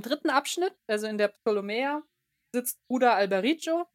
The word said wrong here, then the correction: Alberigo: oder Al nee, Alberigo spricht man es, glaube ich.